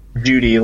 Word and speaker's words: Judea: Judy, as in D-U-T-Y. – Judy, like spelled J-U-D-E-A, but like pronounced like J-U-D-Y.